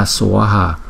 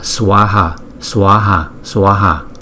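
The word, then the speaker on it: svāhā: Hai chữ A có dấu gạch ngang trên đầu chúng ta đọc là swaha swaha swaha.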